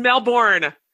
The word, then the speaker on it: Melbourne: And gentle listener, I just want you to realize, if you're an American, listen to the word Melbourne. It's spelled M-E-L-B-O-U-R-N-E, but it's not pronounced Melbourne. It drives me crazy. – It's Melbourne.